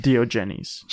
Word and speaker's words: Diogenes: Di- it's pronounced Diogenes.